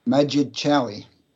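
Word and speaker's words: Madjid Chaoui: Well, it's spelled M-A-D-J-I, and then that's Majid. J-I-D, sorry, Majid, and his family name is Chaoui, C-H-A-O-U-I.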